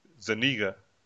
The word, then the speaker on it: Zuniga: Zuniga. Not what you said, which is he said the nigger is what you said, or something like that. But it's Tony Zuniga. That's how I say it's pronounced. How you gonna say? How's it gonna be Zuniga? But it is only one G. It just doesn't have. I just don't see Zuniga. It's Zuniga. Tony De Zuniga, Inker.